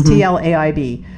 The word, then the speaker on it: Tlaib: And I'm going to try to pronounce her name again. Tlaib, maybe. – Mm-hmm, it's Tlaib.